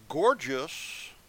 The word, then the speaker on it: Gorgias: Now, gorgeous, that's spelled G-O-R-G-I-A-S.